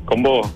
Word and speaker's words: Combó: Supongo que se pronuncia, Felipe, ¿tu apellido? ¿O Combó? – Exactamente, Combó, Rodrigo. – Combó, excelente. Es como un combo, pero con el acento en otra parte.